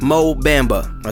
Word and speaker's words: Mo Bamba: Sheck West, and it's pronounced Mo Bamba. Okay, I had it wrong.